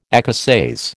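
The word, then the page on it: écossaise: American English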